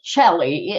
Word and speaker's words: Chellie: My name is Chellie.